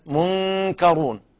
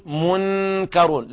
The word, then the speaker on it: منكرون: أيضا في كلمة منكرون، هنا الكاف بعيدة من النون، فيكون النطق قريب يميل إلى الإظهار قليلا، منكرون، ولا نقول منكرون، لا نظهر النون، بينتنا أن طرف اللسان لا يذهب إلى مخرج النون،